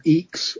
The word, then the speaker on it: IAX: IAX, or EX, as it's pronounced,